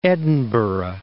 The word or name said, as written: Edinburgh